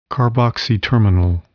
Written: carboxy-terminal

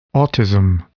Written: autism

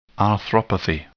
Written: arthropathy

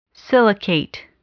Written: silicate

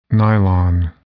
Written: nylon

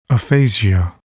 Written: aphasia

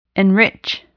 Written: enrich

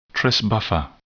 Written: Tris buffer

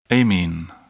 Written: amine

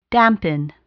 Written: dampen

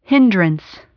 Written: hindrance

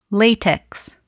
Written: latex

